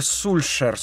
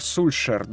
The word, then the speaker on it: Сульшер: Олег Гуннар Сульшер, Сульшер, да, так договорились на произносить его фамилию, человек, которого мы помним, его функция заключалась в следующем.